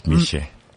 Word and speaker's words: Michee: Michee, you know, we're speaking off air and I kept getting his name wrong because it's spelled M-I-C-H-E-E. So I was like, oh, Michee or Michee. And he's like, no, my name is Michee, but you must put the French accent. And if you don't have the French accent, then you cannot say my name correctly.